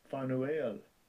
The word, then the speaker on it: Penuel: She's the daughter of Penuel. Penuel. Let's hear the pronunciation. – Strong's G 5323. Penuel.